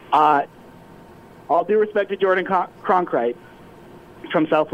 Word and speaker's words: Cronkrite: Jordan Cronkrite, I think I'm pronouncing that name right, but I'm probably wrong. – Yep. – I apologize. Yeah, the Cronkrite rhymes with concrete.